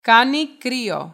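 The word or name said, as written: Κάνει κρύο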